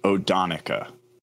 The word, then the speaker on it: Odonnchadha: So apparently it's pronounced Odonica.